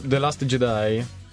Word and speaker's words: The Last Jedi: Esce, aspetta che la so, The Last Jedi. – Esatto. – Detto proprio con la pronuncia giusta. – Pronuncia British perfetta.